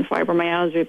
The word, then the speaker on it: fibromyalgia: Oh, you're very welcome, Lauren, and thanks so much for taking an interest in fibromyalgia, because it's often overlooked.